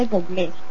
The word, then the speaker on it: Bublé: Algunos dicen que Bublé porque tiene acento en la E, pero bueno, como lo entiendan, es el mismo güey. – Ah, pues el músico se dice Bublé.